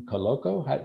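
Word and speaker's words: Koloko: Next guy I've got on my list is Christian Coloco.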